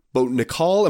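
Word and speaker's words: Nickal: So we're thinking this fight for Donnie Beard against Bo Nickel. Am I saying it right? – Yeah.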